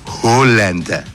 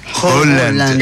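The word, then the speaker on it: Haaland: La doble A... – ¿Sí? – En Noruega es Holland. – Ya. – ¿Cómo? – Holland.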